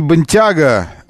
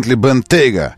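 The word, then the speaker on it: Bentayga: Еще Бентейга, Бентли, не Бентяга, а Бентейга.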